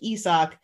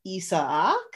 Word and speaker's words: Isaac: It's like it's a three syllable intro now, I think, instead of like Isak, it's Isaac. – Yeah. Yep. – Question mark? – Yeah. Or you kind of blur it together like Isak. You like you just have to drag out the sock and then you're okay.